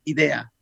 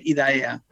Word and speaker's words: IDAEA: esto se tendría que leer idea. ¿eh? Lo que pasa que a pesar de ello, al final todo el mundo lee IDAEA.